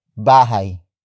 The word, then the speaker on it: bahay: Saan nila dadalhin ang bahay? Saan nila dadalhin ang bahay? Saan nila dadalhin ang bahay? It means, where will they carry the house? Word for word, it's saan nila dadalhin ang bahay?